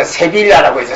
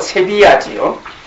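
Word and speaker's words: Sevilla: 스페인이 제일 고약했고 스페인에서도 세비야 잘못 발음을 해서 세빌라라고 해서 세빌라의 이발사 항상 스페인어에서는 L자가 자주 허음이거든요. 쓰기는 쓰지만 발음은 안 한다 하니까 세빌라가 아니고 세비야지요. 세비야의 종교재판관이 얘기를 합니다.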